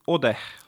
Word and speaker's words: Odeh: teeme alguses sinu sellise ametlikuma tutvustuse ka, sinu nimi on Emmanuel Odeh on niimoodi hääldatakse või, või kuidas? – jah, Emmanuel Ode.